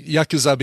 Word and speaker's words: Yakuza: And I've been saying Yakuza because typically in Japanese language, you don't emphasize any syllables. It's not like English where you emphasize a syllable or Spanish where you emphasize the second to last syllable or things like that. I'm probably saying it wrong, but you're the one saying it correctly, I think.